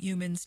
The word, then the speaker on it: Youmans: Yeah, we've been listening to Vincent Heumann's Tea for Two. – Humans. – And it's spelled, right? Y-O-U-M-A-N-S.